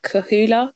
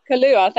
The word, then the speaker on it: Kahlúa: And I'm going to say it completely wrong because I don't know how to say it. Kahula, Kalula, oh, Kahula. – Kahlúa, thank you.